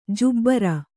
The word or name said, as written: ಜುಬ್ಬರ